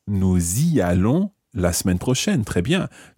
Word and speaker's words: nous y allons: And notice that we pronounce the S of nous y allons. Okay, the nous has the S pronounced here because it's followed by a vowel sound.